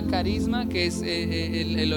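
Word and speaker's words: carisma (χάρισμα): se escribe con CH, charisma, se pronuncia carisma.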